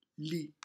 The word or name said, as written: lit